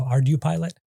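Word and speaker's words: ArduPilot: I would pronounce it along like Arduino, so Ardu pilot.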